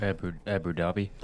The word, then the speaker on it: Abu Dhabi: Abu Dhabi.